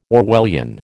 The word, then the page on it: Orwellian: American English